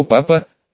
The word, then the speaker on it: папа: Она мягко пожала руку киборга и произнесла: «Так это вы новый механический воин моего папа, о котором все говорят». Она делала элегантное и игривое ударение в слове «папа» на последний слог.